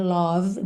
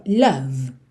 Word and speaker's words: love: Love, lembra que não é love, muito menos love, ok? Ou love. Esse E final não é pronunciado e o O tem aquele som love.